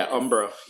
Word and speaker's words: Umbra: Is that how you pronounce it? – Yeah, Umbra.